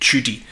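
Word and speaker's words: Tschudi: The name is spelled T-S-C-H-U-D-I. I'm pronouncing it Tudy, and I think that's close enough to the mark.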